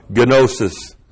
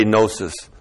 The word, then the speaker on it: gnosis: The conjunction chi plus the dative singular of gnosis. And I know you'll say, no, it's gnosis. Well, there are no silent letters in the Greek. So the Greek would never say gnosis. Let me show you what that looks like here. There it is. G-N long O-S-I-S.